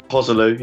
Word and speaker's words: Joselu: I think it's pronounced Hosselu or Joselu. – Yeah, Hosselu.